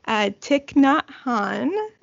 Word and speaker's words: Thich Nhat Hanh: And so you talked about yoga, Tai Chi, and I'm going to do my best to pronounce this correctly, Thich Nhat Hanh.